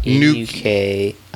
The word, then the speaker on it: Nukie: N-U-K-I-E. – Nuke. – Oh. – Like nuke.